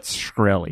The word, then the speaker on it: Shkreli: Like scrunchy, but Shkreli.